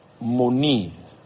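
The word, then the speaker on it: moni (μονή): Meanwhile, it will interest you to know that the Greek word for mansions is moni. Yeah, okay, that's the accurate pronunciation of the word moni, which means abodes or dwellings.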